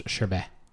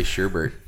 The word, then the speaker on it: sherbet: I think it's pronounced sherbet. – Sherbet. – Yeah. – And I say sherbert.